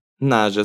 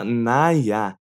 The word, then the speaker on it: naja: Se escreve naja mesmo, mas não se fala naja, se fala "naia".